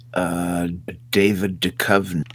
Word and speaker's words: Duchovny: Well, the main two was David Duchovny. Did I say that right? – You did.